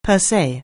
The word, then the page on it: per se: anglais